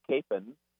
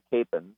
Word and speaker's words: Kapaun: It's spelled K-A-P-A-U-N. It's pronounced either Capon or Capon. And believe it or not, I met his nephew, and his nephew said even the family says it can be pronounced either way. So I usually say Capon.